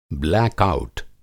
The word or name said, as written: ब्लैक आउट